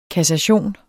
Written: kassation

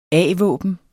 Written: a-våben

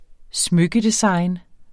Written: smykkedesign

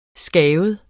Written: skabet

